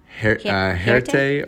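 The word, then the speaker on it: Hjerte: I was also wondering about the black, what, how's it pronounced? Huerte? – Huerte or heart. You can call it heart or black heart or black Huerte if you're saying it. It's a Danish word.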